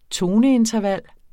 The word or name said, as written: toneinterval